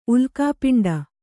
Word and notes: ಉಲ್ಕಾಪಿಂಡ: Kannada

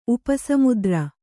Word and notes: ಉಪಸಮುದ್ರ: kannada

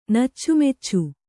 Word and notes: ನೆಚ್ಚುಮೆಚ್ಚು: neccu meccu; Kannada

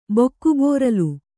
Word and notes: ಬಕ್ಕಬೋರಲು: bakka bōralu

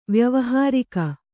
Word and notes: ವ್ಯಾವಹಾರಿಕ: vyāvahārika

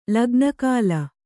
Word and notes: ಲಗ್ನಕಾಲ: lagna kāla; Kannada

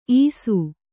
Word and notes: ಈಸು: īsu

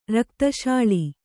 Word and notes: ರಕ್ತಶಾಳಿ: Kannada

